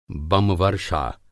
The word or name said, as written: बमवर्षा